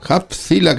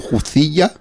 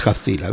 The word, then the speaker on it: Hubzilla: Hubzilla, que bien lo pronuncias además, que yo, yo todo el rato llamándolo Jucilla y es Jacila, bien.